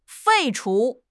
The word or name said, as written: 废除